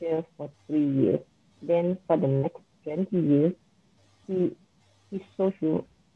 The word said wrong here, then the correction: sojourn: Sojourn? – Oh, yeah.